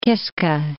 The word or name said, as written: Kiska